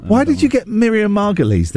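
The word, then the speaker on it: Margolyes: The niece of Miriam Margoyles. – Margulies. – Margulies.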